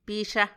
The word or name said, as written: bixa